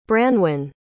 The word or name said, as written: branwyn